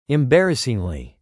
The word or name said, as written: embarrassingly